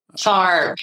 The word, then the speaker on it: Sark: Sark. It even rhymes with Ark.